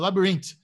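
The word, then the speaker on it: Labrinth: Eu até queria recomendar pra galera, a, não sei se vocês viram, a Nath Croix entrevistou o Labirintite, não Labirintite, Labirinte que é o cara, é o produtor musical de Eufória. – Sim. Eu falei errado, Labyrinth.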